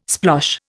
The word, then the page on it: splosh: Indian